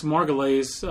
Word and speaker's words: Margulies: And we have Alex Margulies. I think I pronounced his name right. I hope I did. I pronounce it like Juliana Margulies does her name because I think he spells it the same way.